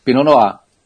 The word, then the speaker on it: Pinot Noir: Pinot Noir. O R sai quase mudo. O R não pronuncia muito no final, não.